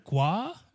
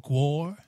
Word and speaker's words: GWAR: Have you heard of Guar or Guar? – How do you spell it? – G-W-A-R.